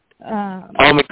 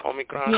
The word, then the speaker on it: Omicron: And now this Omicron, I think that's how you pronounce it. – Omicron. – Now they're saying, Omicron.